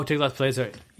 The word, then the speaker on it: Tiglath-Pileser: Oh, Tiglath-Pileser.